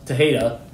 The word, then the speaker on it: Tejada: It's not Tejada. It's Tejada. Like the J is not pronounced at all.